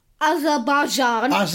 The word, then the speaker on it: Azerbaijan: Azerbaijan.